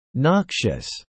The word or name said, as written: noxious